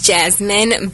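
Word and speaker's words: Jazmine: My name is Jasmine, but my YouTube channel is Jazzy JT, and it's spelled like you would spell my name.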